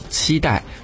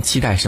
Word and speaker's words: 期待: 还有就是我们内地会讲期待，非常期待什么什么事情，可是在台湾呢会被读作期待。